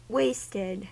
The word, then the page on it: wasted: American English